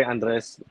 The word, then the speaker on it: Andres: Andres.